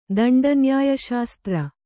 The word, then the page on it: ದಂಡನ್ಯಾಯಶಾಸ್ತ್ರ: kannada